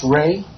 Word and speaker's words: Ray: It's Ray, R-A-Y, period, B-A-U-T-I-S-T-A, at E-E-O-C, period, G-O-V.